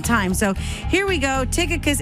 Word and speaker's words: Tychicus: And his name, different people say his name differently, but it's actually Tychicus. Tychicus, some people say Tychicus, is T-Y-C-H-I-C-U-S, but it's Tychicus.